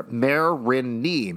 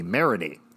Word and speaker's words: Marigny: Marigny. The mare is pronounced like a female horse. Oh, look at that beautiful mare over there. Marigny. Marigny.